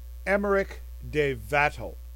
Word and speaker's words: Emmerich de Vattel: Okay, basically in the first paragraph here, I think I'll prefer the pronunciation of Emmerich de Vattel.